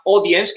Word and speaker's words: Audiense: Hay una con la que llevo trabajando un tiempecito, se llama Audience, que se escribe Audiense,